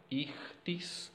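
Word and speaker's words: Ichthys (Ἰχθύς): Ichthys, se dice así, ichthys se dice así en griego.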